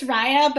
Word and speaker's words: Raya: Raya or Raya. How do you pronounce it? – It's Raya, but, like, they just rejected me, so I feel very upset.